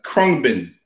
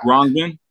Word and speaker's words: Khruangbin: Kurt, uh, Kerbin, uh, Khruangbin, I should say. God, Khruangbin, Khruangbin, yeah.